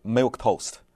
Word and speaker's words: milquetoast: 这个字写在纸上一看是一个怪字，因为它有了这个 Q-U-E，但是它的发音就是 milk toast，就是泡在牛奶里面的面包。